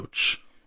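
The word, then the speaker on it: Roache: You know, I want to kind of go back and talk about Linus Roach or Roche. Mm-hmm. What did we agree on as pronunciation? I think Roach.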